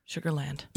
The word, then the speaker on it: Sugarland: It looks like the ghosts of Sugar, hold on, make sure that I say it right. – Land. – Sugarland.